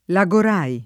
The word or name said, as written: Lagorai